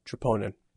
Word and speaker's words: troponin: Troponin.